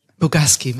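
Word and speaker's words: Bogusky: Also du warst bei BBH London, Jung von Matt, Crispin Porter und, wie spricht man das eigentlich aus? Bogaski, Miami, genau. Dann, also da schon wahnsinnig international, zwar inhabergeführt, aber international.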